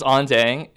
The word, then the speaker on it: An Dang: My name is Ondang. It's spelled A-N, but it's pronounced on, like when you turn on the lights.